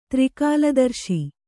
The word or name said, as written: ತ್ರಿಕಾಲದರ್ಶಿ